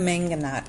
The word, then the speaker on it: mangonada: Uh, I, I, know that you said you personally like the mangonada. – That's one of our staples. That is one of my favorites, and we have to definitely talk about that. So tell us what's in the mangonada.